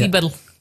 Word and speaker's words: Dieberl: Nämlich ein Dieberl. – Ein Dieberl. – Habe ich es richtig ausgesprochen? Ja. Dieberl. So, es ist Niederösterreicher L, musst du.